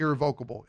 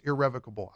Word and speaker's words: irrevocable: It's not irrevocable, it's irrevocable.